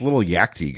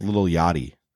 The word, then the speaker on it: Lil Yachty: I go, who's this little Yakti guy? And my brother goes, that's little Yachty.